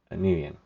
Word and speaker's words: Nguyen: So Beardsley and Dennis both knew a Vietnamese guy named Mike Nguyen. That's how they pronounce it. My ex, her last name is also Nguyen, or in Vietnamese pronunciation is Nguyen.